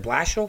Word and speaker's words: Blashill: I'm not sure if I'm pronouncing it. Jeff Blashill? Is it Blashill? – Yeah, correct.